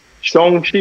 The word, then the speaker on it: Shang-Chi: I almost mispronounced it. – Shang-Chi.